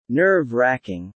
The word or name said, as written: nerve-wracking